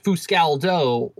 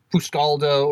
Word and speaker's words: Fuscaldo: It's pronounced Fuscaldo or Fuscaldo or Fuscaldo. It's like you say tomato, I say tomato.